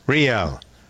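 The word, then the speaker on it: Rielle: Pronounce it again? Riel? Riel. Hunter. – Close enough.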